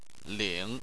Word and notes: ling: 3

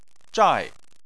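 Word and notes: zhai: tone 4

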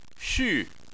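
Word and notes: xu: tone 4

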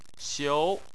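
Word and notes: xiu: xiu2; tone 2

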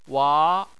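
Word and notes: wa: wa2; tone 2

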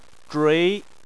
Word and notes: zhui: 2

